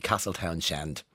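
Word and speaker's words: Castletownshend: Loads of people, thank you so much for telling me, don't pronounce the H. And thank you, Jennifer. I never knew there was a H in Castletown Shend, actually. I'll stop saying that because it's going to really annoy you. We never pronounce the H in it though, Jennifer.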